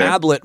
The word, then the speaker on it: Ablett: Ablett rhymes with tablet, yep.